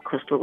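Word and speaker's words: Chrystal: Well, I'm on all social media outlets, but my name is spelled Crystal, C-H-R-Y-S-T-A-L. R-U-C-K-E-R. And it's just that, Crystal Rucker, on all social, on Twitter, Instagram, all social media, Facebook, everything.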